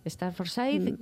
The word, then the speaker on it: Staffordshire: Tenéis a Vincent, que es una América Staffordshire, ¿se dice así? – Staffordshire.